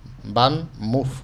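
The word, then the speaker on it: VanMoof: Van Moff. Es una marca holandesa. Van Moof, o Moof, como se pronuncia.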